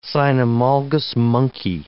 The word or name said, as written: cynomolgus monkey